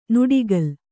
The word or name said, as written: ನುಡಿಗಲ್